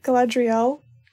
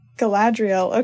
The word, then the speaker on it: Galadriel: And then the next chapter, chapter 7, is called The Mirror of how did you say it? Galadriel. Galadriel, okay.